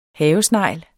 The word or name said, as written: havesnegl